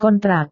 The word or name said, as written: kontrak